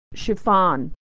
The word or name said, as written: chiffon